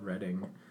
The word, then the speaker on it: Reading: My dad said it was a place in Pennsylvania called Reading. – Wow. – So yeah, it's pronounced it that way.